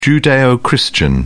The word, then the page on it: judeo-christian: British English